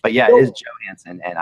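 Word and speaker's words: Johansen: What the heck are you doing when you keep calling the center for the Preds Johansson? He is not from Europe. It is pronounced Johansson.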